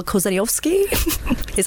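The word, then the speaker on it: Korzeniowski: Abel Kozelowski.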